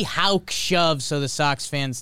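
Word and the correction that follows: Houck: That one's actually pronounced Huak. – Huak. – Like Roark.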